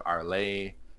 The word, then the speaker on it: Arlais: but it's spelled A-I-L-L-E-S at the end. I have Basto, mm-hmm. I have Caspierre, I have Arlay, which is A-R-L-A-I-S.